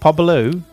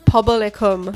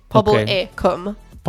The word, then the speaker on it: Pobl y Cwm: Pobl-ou? – Pobl y Cwm. – Pobl-e? – Pobl. So it's Pobl, one word, then E, like, which is the Y. Pobl y Cwm.